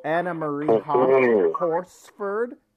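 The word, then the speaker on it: Anna Maria Horsford: Oh, Anna Marie Horsford.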